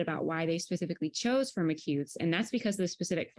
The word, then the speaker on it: Firmicutes: diff episode, we're going to have an I Feel Nerdy segment about the correct pronunciation of Firmicutes. Is it Firmicutes, Firmicutes, Firmicutes? But Kevin Gary says Firmicutes, so that's what I'm going to go with.